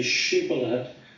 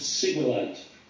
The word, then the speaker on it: Shibboleth: They were not able to say Shibboleth, but would pronounce it Sibboleth.